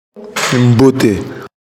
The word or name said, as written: Mbote